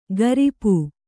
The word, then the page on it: ಗರಿಪೂ: gari pū